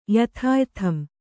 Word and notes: ಯಥಾಯಥಂ: yathāyatham

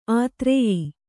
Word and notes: ಆದೊರೆಯ: Kannada

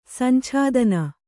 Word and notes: ಸಂಛಾದನ: Kannada

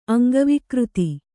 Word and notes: ಅಂಗವಿಕೃತಿ: Kannada

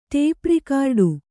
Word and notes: ಟೇಪ್‍ರಿಕಾರ್ಡು: ṭēp rikārḍu

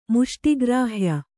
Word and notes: ಮುಷ್ಟಿಗ್ರಾಹ್ಯ: muṣṭi grāhya; Kannada

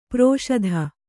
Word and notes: ಪ್ರೋಷಧ: prōṣadha

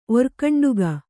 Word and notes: ಒರ್ಖಂಡುಗ: orkhaṇḍuga

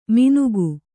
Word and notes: ಮಿನುಕು: minuku

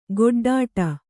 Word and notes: ಗೊಡ್ಡಾಟ: goḍḍāṭa

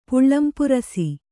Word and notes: ಪುಳ್ಳಿಂಪುರಸೆ: Kannada

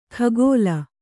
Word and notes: ಖಗೋಲ: khagōla

